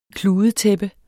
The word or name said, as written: kludetæppe